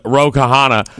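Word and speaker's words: Ro Khanna: So a Democratic congressman, Rokana. The H is silent.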